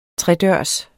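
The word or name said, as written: tredørs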